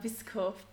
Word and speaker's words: VSCO: Und ähm, Whisk wie spricht man es aus? Whisker? – Ich sage immer Visco. VSCO.